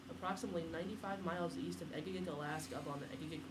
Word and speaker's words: Egegik: They found footprints east of Egigik, E-G-E-G-I-K. How do you say that? Egigik? – Yeah, Egigik.